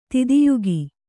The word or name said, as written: ತಿದಿಯುಗಿ